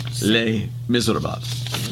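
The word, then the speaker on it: Les Misérables: The Los Miserables. – I have a hard time with that because it's so many syllables. – Los Miserables. – Okay. Les Miserables.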